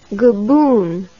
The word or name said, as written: goboon